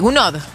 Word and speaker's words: Gounod: Claro que sí, el concierto propone un recorrido por obras de grandes compositores de la ópera como Verdi, Puccini, Mascagni y Gaunt. ¿Cómo se dice? Gounod, ¿cierto? ¿Uno está bien dicho? – Sí.